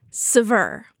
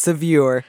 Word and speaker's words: Saveur: Helen, how do you pronounce the second magazine in your bio? – Sever. – Sever. Oh, I would never have guessed that. Thank you. I would have said Sevier.